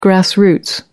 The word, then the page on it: grass roots: British English